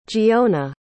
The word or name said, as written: geona